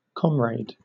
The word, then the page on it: comrade: British English